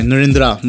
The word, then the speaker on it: Narendra: Sorry, I pronounced his name wrong. I said Narendra, sorry. Narendra Modi, my bad.